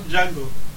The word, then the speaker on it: Django: You might as well come and watch Django. Stop watching Kill Bill for the 14th time. It does look awesome, though. It really does. – So, Douglas? – D is silent.